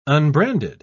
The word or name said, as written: unbranded